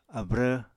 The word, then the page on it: abrv: Apatani